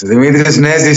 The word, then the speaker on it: Dimitris Nezis: With flesh and bones, Dimitris Nezis, straight from Athens, Greece.